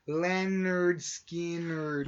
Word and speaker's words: Lynyrd Skynyrd: So, Gimme Three Steps is a song by Southern rock band Lynyrd Skynyrd, released on its 1973 debut album, pronounced Lynyrd Skynyrd.